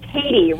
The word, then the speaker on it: Tady: Actually, Tady rhymes with lady.